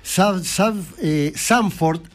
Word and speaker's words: Zandvoort: Y realmente, Países Bajos, última carrera de la histórico trazado, Zandvoort, así se pronuncia, es inaugurado uno de los más antiguos.